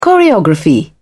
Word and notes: choreography: British English

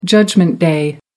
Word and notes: judgment day: American English

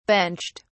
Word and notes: benched: British English

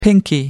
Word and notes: pinkie: American English

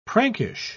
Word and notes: prankish: British English

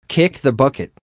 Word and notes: kick the bucket: American English

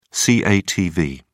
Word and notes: catv: British English

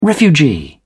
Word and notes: refugee: British English

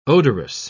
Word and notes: odorous: British English

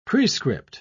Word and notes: prescript: British English

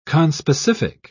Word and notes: conspecific: British English

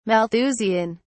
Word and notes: malthusian: British English

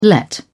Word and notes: let out: British English

